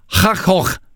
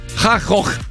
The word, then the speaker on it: Van Gogh: E Martin Deron è olandese e ha detto l'esatta pronuncia di Van Gogh. – Cioè? – Che è Hachoch. – Non è vero. – Ti giuro. Hachoch.